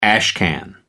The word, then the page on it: ashcan: American English